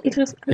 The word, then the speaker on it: Idris Elba: Spricht man den so aus, Idris Elba? – Ja.